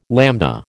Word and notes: lambda: American English

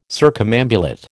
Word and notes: circumambulate: American English

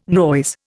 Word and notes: noise: Indian

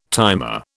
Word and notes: timer: British English

